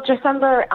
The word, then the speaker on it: Dressember: And I know you've put a good cause in front of you as well with Dressember. Am I pronouncing that right? – Yeah. Yes, you are. – Can you tell us a little bit about that? – Yes, you are. Well, Dressember is basically like a scholarship program,